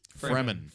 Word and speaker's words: Fremen: Fremen.